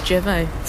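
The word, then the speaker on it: Jervaulx: Jervaux blue. The shrews decided. A blue Wensleydale. – Ooh. – Oh, look, he's written, as pronounced in French, Jervaux.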